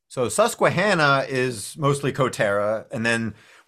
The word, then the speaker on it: Susquehanna: Is it I always find it interesting because is it Susquehanna County? Is that how you pronounce it? – Susquehanna.